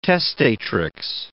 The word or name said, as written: testatrix